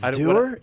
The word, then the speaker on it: dour: It's doer and it's schism. – You have some, you have very precise pronunciations. – Wow, no, I hate this, but. It looks like maybe both pronunciations are right, but when I clicked the pronunciation guide, it said doer.